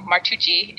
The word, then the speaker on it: Martucci: you realize the correct pronunciation of a name of Italian origin that ends in U-C-C-I should be Ucci. So Martucci